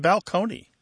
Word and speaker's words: balcony: My favorite change that I love to talk about when Martha and I go and do live events in the rows is the word balcony, you know, like this place on a stage that you're going to look down upon the seats, used to be pronounced balcony.